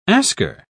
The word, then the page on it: asker: British English